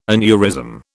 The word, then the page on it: aneurysm: British English